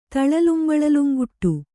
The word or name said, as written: ತಳಲುಂಬಳಲುಂಗುಟ್ಟು